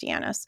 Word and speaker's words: Janis: So with groupthink, Irving Yanis, his name is J-A-N-I-S, but I think it's pronounced Yanis.